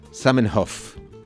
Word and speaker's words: Zamenhof: Zamenhof. No sé si lo dije bien, pero... – Sí, perfecto.